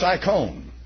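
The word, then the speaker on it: icon (εἰκών): Or icon, if you pronounce it correctly in Greek, I think it's got an omega, and omega is always pronounced O, not ah. Omicron is pronounced ah. So it's icon.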